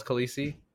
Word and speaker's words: Khaleesi: On one hand, that's like, it's like, there's going to be so many kids called Khaleesi, but at least this one's different.